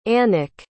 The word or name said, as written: annikke